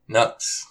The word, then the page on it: Nuks: Yes!